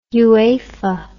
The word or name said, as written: UEFA